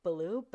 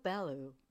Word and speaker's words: Ballew: Baloo. Like the bear in the Jungle Book, if you remember that character. – I know. – Phonetically, it would be spelled B-U-H-B-A-L-O-O. Baloo. – Oh, yeah. Yeah. – But it's spelled B-A-L-L-E-W.